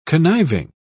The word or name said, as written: conniving